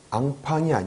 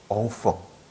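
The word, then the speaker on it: enfant: 예, 앙팡이 아니에요. 한국식 표기할 때는 앙팡이라고 표기하는데요. 프랑스에서 발음할 때는 엉펑. 엉펑 하셔야 돼요.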